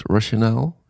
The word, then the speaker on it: Rationale: Ehm, hoe spreek je dat uit? Rationale? – Ja.